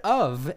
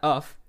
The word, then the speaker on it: of: When you just say the word of, and it's got a V sound at the end of it. It's not uff.